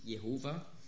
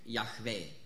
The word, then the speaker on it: Yahweh: Dort wird der Gottesname wiedergegeben mit Jehova. Im Hebräischen wäre eigentlich korrekt die Aussprache Yahweh.